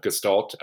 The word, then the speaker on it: gestalt: I will also tell you that I am using the British enunciation of gestalt with a hard G.